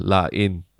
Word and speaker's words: La Haine: H-A-I-N-E. Line. Yeah. Like, but it's basically the L-A-H-A-I-N-E. – Yeah. La In. Like, okay. Yeah, but H is silent in French.